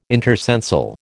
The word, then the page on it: intercensal: American English